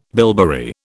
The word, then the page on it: bilberry: British English